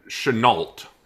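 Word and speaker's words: Chennault: You write that the Cheno affair, is that how it's pronounced? It's Chenault. – Chenault, okay. Was perhaps one of the few truly treasonous actions that has occurred that can be attributed to Nixon.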